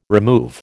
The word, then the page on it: remove: American English